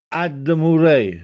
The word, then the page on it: Addemurè: Castellanetano